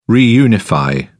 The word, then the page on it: reunify: British English